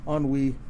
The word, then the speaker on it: ennui: Ennui...